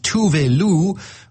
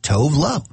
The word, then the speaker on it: Tove Lo: Incidentally, she says back home in Sweden, her name would be pronounced Tove Lo, but since we're not in Sweden, you can just call her Tove Lo.